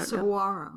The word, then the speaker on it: Saguaro: It's named after the cactus and I keep mispronouncing that. I don't know. – Saguaro. – There you go.